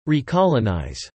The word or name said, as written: recolonise